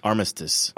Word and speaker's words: Armistice: Armistice.